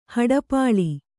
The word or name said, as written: ಹಡಪಾಳಿ